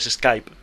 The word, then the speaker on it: Skype: Ya lo sé, pero es que como cuando lo conoces por escrito sin querer tiendes a castellanizar la pronunciación, sí. – Entonces dices pues Skype. Pero es Skype en todo el planeta, yo diría.